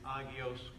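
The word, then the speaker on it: hagios: Agios with an H is how it's spelled. The H is silent. Agios, agios.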